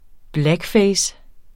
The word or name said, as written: blackface